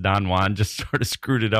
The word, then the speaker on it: Don Juan: But in the case of Byron and Don Juan, it is Don Juan. We have to pronounce it that way because Byron was writing poetry and it was in meter, and the meter and the rhythms and the rhymes don't work if you say Don Juan.